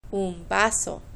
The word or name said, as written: un vaso